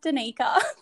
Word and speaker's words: Danica: My name's pronounced Danica.